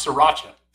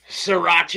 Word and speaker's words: Sriracha: How do you pronounce sriracha? – Sriracha.